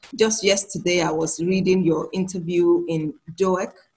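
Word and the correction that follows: Doek: It's Duk. Duk. It's Afrikaans. So I don't expect you to get it right.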